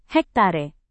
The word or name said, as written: hektare